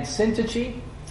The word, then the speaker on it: Syntyche: Now, next week when we begin our discussion, we're going to talk about Euodia and Syntyche. And I do believe that's the correct pronunciation. I looked it up once.